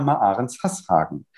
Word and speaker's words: Marahrens-Hashagen: Darüber spreche ich mit der Präsidentin der Handelskammer Bremen, Janina Maarens-Hasshagen. Ganz herzlich willkommen, Frau Maarens-Hasshagen, hier bei Friedrichs Flaschenpost, dem Politikpodcast aus Norddeutschland. – Ja, schönen guten Tag, Sie haben meinen Namen ja sehr unfallfrei ausgesprochen.